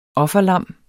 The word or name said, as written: offerlam